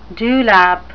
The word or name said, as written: dewlap